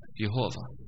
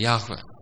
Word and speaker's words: Yahweh: her er det hebraiska Jehova. Det er Yahweh som er den rattere uttalen.